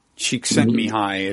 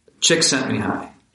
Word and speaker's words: Csikszentmihalyi: This is an idea most famously associated with Csikszentmihalyi is one pronunciation of that formidable last name. – Csikszentmihalyi.